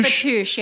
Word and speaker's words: Fattoush: How do you say that, right? Fattoush? – Fattoush, yeah.